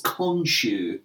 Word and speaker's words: Khonshu: He gets all messed up and he sort of bleeds out under some Egyptian statue of, what the pronunciation of it is, Khonshu. – Yes, that's it, yeah.